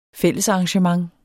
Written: fællesarrangement

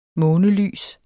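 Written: MÅNELYST

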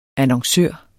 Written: annoncør

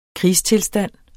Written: krigstilstand

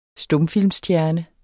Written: STUMFILMSSTJERNE

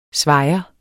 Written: svajer

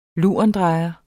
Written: lurendrejer

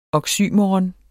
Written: oxymoron

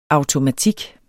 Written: automatik

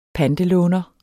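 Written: pantelåner

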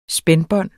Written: spændebånd

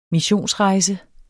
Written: missionsrejse

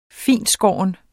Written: fintskåren

